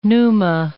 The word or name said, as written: pneuma